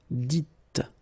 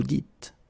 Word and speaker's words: dites: So it's not vous disiez, okay, as many would think, but then it's vous dites, okay, final S not pronounced, vous dites.